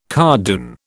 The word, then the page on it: cardoon: British English